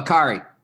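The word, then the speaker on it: Acciari: I thought that they, you look at the way that DeBrusque, for example, I know I'll say this kid's name wrong, but Akiari? – Oh, Akari. Noel Akari. – Akari. See? I knew I'd get it.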